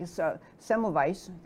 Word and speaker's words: Semmelweis: So is this your term, John Leake, Semmelweis,